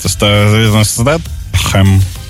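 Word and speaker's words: Statham: Стэтхэм. – Тэм. – Смотри, он британский актер. Британский. В Британии с произношением там Стэтхэм. Вот так вот оно надо... – А британцы именно... там скажут Стэтхэм.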